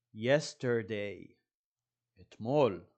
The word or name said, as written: yesterday